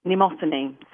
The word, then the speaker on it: Mnemosyne: Mnemosyne.